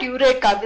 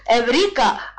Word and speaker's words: Eureka: It's not Eureka, that's the English pronunciation. The Greek pronunciation is Eureka.